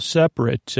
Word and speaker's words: separate: Separate is separate, S-E-P-A-R-A-T-E. The second vowel sound is spelled with an A and not a U or an E, even though it does sound like that sometimes. And then finally, their last word is lightning, L-I-G-H-T-N-I-N-G. L-I-G-H-T-N-I-N-G.